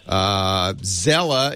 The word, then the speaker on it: Zelle: Zelle is used by banks now. I don't know how you pronounce that. Z-E-L-L-E, Zelle.